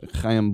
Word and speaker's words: Chaim: And Chaim Bloom, and I'm not making fun of him, that's how you pronounce it, Chaim Bloom, the GM there, he said to the Boston Herald, quote, "We've dug ourselves a hole.